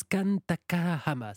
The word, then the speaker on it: Kan Takahama: Mais version manga par la japonaise Kan Takahama, c'est ça ? – Oui, très bien prononcé, bravo pour la prononciation.